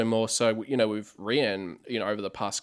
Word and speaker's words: Rian: So that was really cool, just seeing you know, the three announced with Ryan, or I should say it's now pronounced, I think it's pronounced Rian, isn't it? – I believe it's Rian.